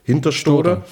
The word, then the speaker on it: Hinterstoder: Und dann gibt es in Hinterstoder, wie spricht man es aus? – Hinterstoder.